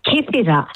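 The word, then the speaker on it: Kythira: I said Kithira. Hang on. How do you say the island again? – You said it right. Kithira.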